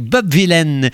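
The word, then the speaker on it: Bob Vylan: On a commencé ce Un Poco Too Much avec Bob Villain. Et oui, c'est comme ça que ça doit se prononcer, paraît-il. Pourtant, ça s'écrit comme Bob Dylan, mais avec un V.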